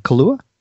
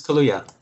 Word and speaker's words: Kaluuya: Daniel, how do you say his name? Kaluuya? – I believe it's Kaluuya.